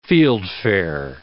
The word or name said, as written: fieldfare